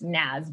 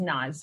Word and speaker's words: Naz: It's actually pronounced Nas, but everyone says Nas, so don't feel bad.